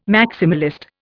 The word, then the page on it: maximalist: Indian